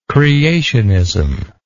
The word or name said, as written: creationistic